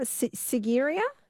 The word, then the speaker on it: Sigiriya: Now, Paul says in now, what was the pronunciation of where you are? Sigiriya? Yes.